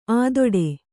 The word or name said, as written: ಅಧಟು